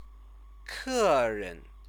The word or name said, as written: keren